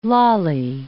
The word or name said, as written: lally column